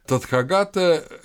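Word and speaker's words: Татхагата: Тадхагата – это тот, кто достиг, или тот, кто пришел. Если делать иначе ударение, то получается тот, кто ушел.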